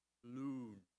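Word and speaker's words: loon (לון): The Hebrew word, and I'll say it in Hebrew for you, is loon. Loon. Like Loon Lake. Same way to pronounce the word for the Hebrew word for murmuring or grumbling is loon.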